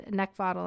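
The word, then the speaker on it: Neckvatal: And if you'd like to connect with me professionally, you can also follow me on LinkedIn over at Brenda Neckvottal. And my last name is spelled N-E-C-K, V as in Victor, A-T-A-L.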